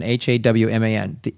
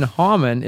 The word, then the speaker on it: Hawman: The haw, her last name is Hawman, Hawman. She writes, the haw in Hawman is like the haw in hee haw, and the man is like the min in your Spider-Man, the way I've been pronouncing Spider-Man.